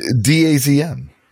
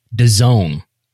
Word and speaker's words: DAZN: I call it DAZN. Is it not pronounced that? – So what's the spelling? Tell everybody what the spelling is. – DAZN. – DAZN. – DAZN. – Is how you pronounce that brand.